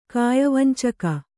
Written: ಕಾಯವಂಚಕ

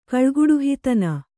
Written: ಕಳ್ಗುಡುಹಿತನ

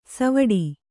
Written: ಸವ್ವಡಿ

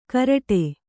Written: ಘರಟ್ಟ

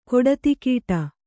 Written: ಕಡುದುಗುಡ

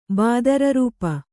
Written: ಬಾದರರೂಪ

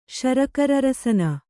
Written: ಶರಕರರಸನ

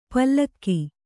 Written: ಪಲಕು

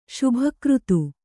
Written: ಶುಭಕೃತು

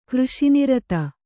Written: ಕೃಷಿನಿರತ

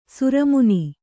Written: ಶ್ರಮಣ

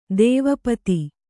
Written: ದ್ವಿಪದ

